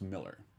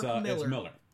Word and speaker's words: Millar: Alan Kissler would correct you and say that it's pronounced Miller. – Is it? – Yeah. – Always look like Millar. – He corrects me every single, it looks like Millar, right. – But it's Miller. He's like, no, that's the way, it's the British pronunciation of the A.